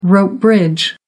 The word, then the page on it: rope bridge: American English